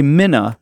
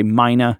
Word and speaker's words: mina: But so a minna, just for those who is listening, and some people, again, I love what you said about pronunciation. Some might say mina, if you're wondering, and so it's minas, plural, or minas.